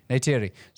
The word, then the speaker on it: Neytiri: Neytiri.